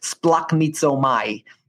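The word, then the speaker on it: splachnizomai (σπλαγχνίζομαι): You can go look it up, the spelling later, but it's pronounced this way, splachnizomai.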